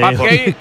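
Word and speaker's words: Pape Gueye: Pap Gay, el que llamamos Pape Gay en todo el mundo, sí, es Pap Gay.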